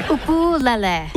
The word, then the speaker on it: ukulele: Peter's first question to Taimane: how the hell do you pronounce ukulele? – Ukulele. – Ukulele. – Ukulele. You got it.